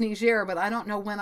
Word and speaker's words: Niger: The country is spelled N-I-G-E-R. Looks like Niger. I believe it's Niger, though.